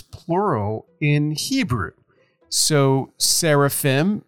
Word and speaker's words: seraphim: That I-M at the end, if you were pronouncing it more correctly, would sound like an E-M, not an M. So instead of seraphim, we would say seraphim. And instead of cherubim, we would say cherubim.